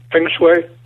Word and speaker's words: Peng Shuai: Am I pronouncing that name right, by the way? – Peng Shuai? Yeah. – Shuai? – Close enough.